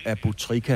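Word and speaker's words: Aboutrika: Hvem er denne Mohammed Abutrika, Trika, hvis jeg udtaler ham rigtigt? – Det gør du, det gør du.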